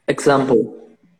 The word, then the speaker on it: example: Você acabou pronunciando com o som de Z, né? Example. Tenta pronunciar isso como se tivesse um G ali, assim, ó.